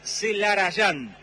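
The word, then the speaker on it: Zelarayán: pero en realidad, tanto en su documento, que es lo más importante, como en su camiseta, dice Celarrayán, porque se escribe y se dice así.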